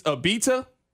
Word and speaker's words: Abita: It's Abita.